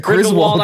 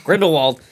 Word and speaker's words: Grindelwald: I think it's Grindelwald. – Or Grindelwald. – You said Griswold. – I'm sorry. – I'm like, Clark Griswold? – I totally said it wrong. Grindelwald.